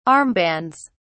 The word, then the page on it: armbands: British English